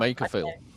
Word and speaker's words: Makerfield: So I think is it Makerfield or Macafield? I'm not a northerner, so I don't know how to pronounce that. – Makerfield. – Makerfield. Okay.